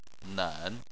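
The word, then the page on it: nen: tone 3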